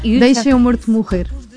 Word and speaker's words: Deixa-me Morto Morrer: It's in Portuguese, it's very hard to pronounce Portuguese, but it's almost Portuguese. – No, Deixa-me Morto Morrer.